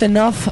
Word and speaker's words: enough: Not Enough For You. – Not enough. Se dice enough.